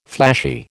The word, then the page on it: flashy: American English